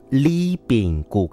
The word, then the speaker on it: Ribingu: 他们叫做 Li Bing Gu，虽然是发音 Li Bing Gu，可是它的第一个英文字母叫做 R 哦，R-I-B-I-N-G-U，客语的双人组合。